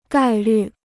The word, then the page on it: 概率: gài lǜ